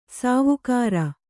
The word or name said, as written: ಸಾವುಕಾರಿ